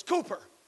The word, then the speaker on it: Cowper: Now in our songbooks it's spelled C-O-W-P-E-R. Until I went to Cooper's house in Olney, England, I thought that's how you pronounce C-O-W-P-E-R was Cooper. I mean Cowper. But it's pronounced Cooper over there.